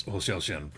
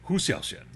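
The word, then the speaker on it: Hou Hsiao-hsien: I finally learned how to pronounce Ho Xiao Xian properly last night. Because I had been saying Hu Xiao Xian.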